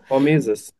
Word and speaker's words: Hormesis: Habe ich das richtig ausgesprochen? Hormesis, ne? – Genau, Hormesis, ja.